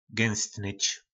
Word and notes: gynstnyc: po nasimu

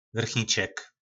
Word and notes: vrchnicek: po našimu

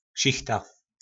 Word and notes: sichta: po nasimu

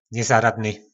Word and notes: nezaradny: po nasimu

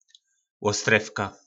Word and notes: lostrevka: po nasimu

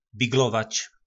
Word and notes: Biglovac: po nasimu

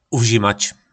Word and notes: uvzimac: po nasimu

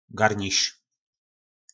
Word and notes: garniz: po nasimu

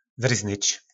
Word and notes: vyrznyc: po našimu

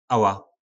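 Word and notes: ala: nářečí Po našimu

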